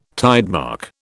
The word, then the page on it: tidemark: British English